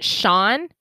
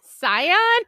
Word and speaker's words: Cian: So his name is spelled C-I-A-N. – That's Celtic. Is that Sean? No. – You like took a Harvard left on that one. No. So Scion? – That's what I said.